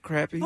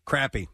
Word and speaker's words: crappie: Crappy. That's how you pronounce it in the South, yep. – What do y'all call it? – When I lived in the Midwest, it was called crappie.